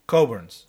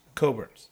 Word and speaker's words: Cockburn's: Or it's properly pronounced, apparently. – Coburn's. – It's Cockburn's. – C-O-C-K. K-B-U-R apostrophe. B-U-R-N apostrophe S. – Which is Cockburn's. – Coburn's.